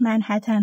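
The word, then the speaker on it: منهتن: ما توی این اپیزود از سنترال پارک و نهزت پارکسازی گفتیم و هم که در بعضی قسمت های اپیزود کلمه منحتن رو اشتباهی تلفظ کردیم. تلفظ صحیحش منحتن هست از این بابت هم خواهیم.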